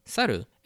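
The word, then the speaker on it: saru (猿): Is, you know, in Japanese, monkey is pronounced saru.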